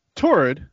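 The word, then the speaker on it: Taured: His passport was from a country named Tirad. I might be mispronouncing. It's kind of like Taurus, but with an "id" at the end. Torrid. Torrid, I bet is what it is, right? – T-A-U-R. – I would say Tarid. Yeah. – Red. – So, Torrid. – Very nice. – Which is, I've been mispronouncing, so live with that.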